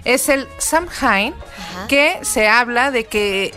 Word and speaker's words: Samhain: realmente la palabra es Samhain o Samhain y se pronuncia Samhain, que quiere decir literalmente el fin de verano.